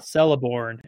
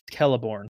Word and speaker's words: Celeborn: I remember this from the pronunciation guide that CEs are pronounced K. And so it's not Celeborn, it's Celeborn.